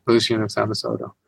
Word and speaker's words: Lucian of Samosata: Lucian of Samosata? – Lucian of Samosata.